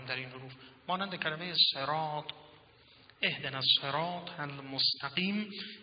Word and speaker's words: صراط: این کلمه صراط رو اگر شما با سین هم تلفظ کنید ایرادی ندارد. حتی بعض از فقیهان قرائت با ز رو هم قبول میدونن، در کلمه زراط میشه نه صراط.